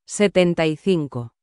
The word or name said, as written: 75